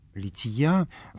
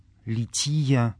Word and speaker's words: лития: В предыдущих наших занятиях мы обратились к такой группе текстов из службы праздника Рождества Пресвятой Богородицы, как стихиры на литии, лития, церковнославянское ударение в этом слове, лития.